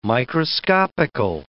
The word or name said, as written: microscopical